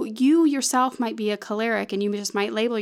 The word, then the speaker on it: choleric: It's pronounced choleric or choleric.